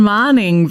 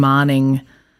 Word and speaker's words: Marning: Well, I'm Ellen Marning, which I always say is like morning, but with an A.